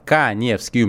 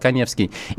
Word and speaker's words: Каневский: Подписывайтесь на мой инстаграм, Эдуард Каневский, у меня инстаграм с галочкой, фамилия пишется через А, Эдуард Каневский.